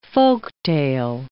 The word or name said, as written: folktale